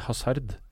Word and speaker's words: Hazard: Jeg sier Azar. Azar? Ja, jeg tror han kommer fra den franske delen av Belgia, og da uttaler de vel ikke H-en. – Jeg sier Hazard.